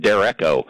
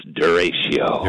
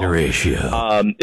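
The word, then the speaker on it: derecho: And because the first time I pronounced it back when 40-some years ago, I pronounced it Dereco. – That's probably what I would have done. – I was corrected. No, it's Duratio.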